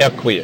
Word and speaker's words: Equi: First of all, am I pronouncing that correct? – It's Equi. – Equi. – Yeah, okay. Like equity.